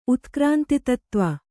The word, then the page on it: ಉತ್ಕ್ರಾಂತಿತತ್ತ್ವ: utkrāntitattva